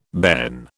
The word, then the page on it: bairn: British English